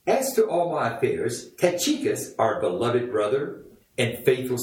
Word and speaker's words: Tychicus: And his name is Tychicus. And that is the way you're supposed to pronounce it. I looked it up.